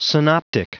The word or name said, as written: synoptic